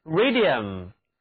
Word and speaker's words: radium: 最后来看一看人类所发现的第一个放射性元素雷，就是 radium， radium， UM 这个后缀表示化学元素，那么字母组合 IU 读作 U， U。